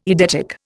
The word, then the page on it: eidetic: Indian